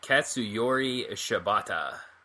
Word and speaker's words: Katsuyori Shibata: And it involved Tomohiro Ishii and Katsuyori Shibata.